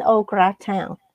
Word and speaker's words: au gratin: say it, but I can't pronounce it. Chicken au gratin. – Oh yeah. Well, you pronounce it just fine.